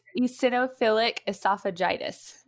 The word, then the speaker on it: eosinophilic esophagitis: And EOE is, okay, I'm going to say this wrong, but like esophiliac esophageal. Okay, I'm sure I didn't do it right. Help me out. – You're close. It's eosinophilic esophagitis.